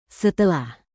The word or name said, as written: setelah